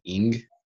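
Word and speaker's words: Ng: I'm going to mess up her last name. – Yeah. – Nung. – I think it's pronounced like Ing.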